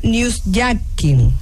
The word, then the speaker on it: newsjacking: Hay uno que se llama newsjacking. ¿Está bien pronunciado? – Sí, viene de hijacking.